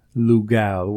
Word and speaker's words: Lugal: If I'm not, it's spelled L-U-G-A-L. And I learned that L-U-Lu means man, and gal means either great or big man.